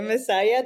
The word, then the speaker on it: Macias: Second, I like the way you pronounce my last name, by the way. Macias doesn't sound anything wrong.